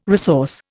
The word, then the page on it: resource: Indian